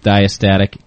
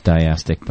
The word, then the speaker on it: diastatic: Diastatic. – Thank you. – It was written as diastic, by the way. – Well, because, you know, she doesn't know how to pronounce it.